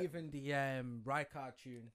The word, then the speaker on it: Rijkaard: Make that Rijkaard rhymes with Bicarb.